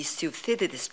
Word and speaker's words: Thucydides: The Thucydides trap. I hope I pronounced it well. – You pronounced it exactly right.